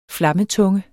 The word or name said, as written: flammetunge